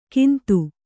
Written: ಕಿಂತು

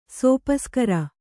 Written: ಸೋಪಸ್ಕಾರ

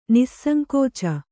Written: ನಿಸ್ಸಂಕೋಚ

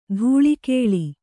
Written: ಧಾಳಿಗೊಳ್

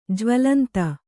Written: ಜ್ವಲಂತ

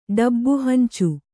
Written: ಡಬ್ಬುಹಂಚು

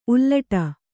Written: ಉಲ್ಟಾ

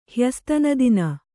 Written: ಹ್ಯಸ್ತನದಿನ

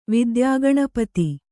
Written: ವಿದ್ಯಾಗಣಪತಿ

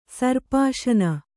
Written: ಸರ್ಪಾಶನ

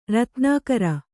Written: ರತ್ನಾಕರ

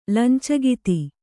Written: ಲಂಚಗಿತಿ

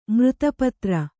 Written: ಮೃತ್ಪಾತ್ರ

